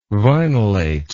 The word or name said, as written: vinylate